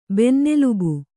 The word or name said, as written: ಬೆನ್ನೆಲುಬು